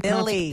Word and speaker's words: Millie: Miley's, or what is her name? How do you Millie. – Pronounce it? Millie.